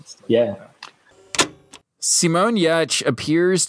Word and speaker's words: Giertz: Giertz eventually started her own product design company called Yetch, spelled Y E T C H, phonetically spelling her last name.